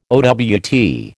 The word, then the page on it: owt: American English